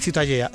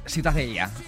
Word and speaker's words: Ciutadella: La catalán? Ciutadella. – Ciutadella. Bueno, pues Ciudadela también en castellano. – Ciudadela, bueno, sí. – Pues eso, ha quedado clarísimo. ¿Para qué se meten? – ¿Tú cómo lo pronuncias? – Ciutadella. – Mira, muy bien.